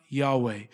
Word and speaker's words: Yahweh: You see, when it's in all capital letters, that is the English translation for YHVH, which we believe it is pronounced Yahweh.